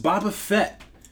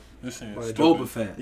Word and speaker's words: Boba Fett: Next on the list, Boba Fett makes his return. That's a joke, right? – What? – You pronounce his name wrong. – Boba. – Boba Fett.